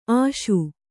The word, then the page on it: ಆಶಿ: āśi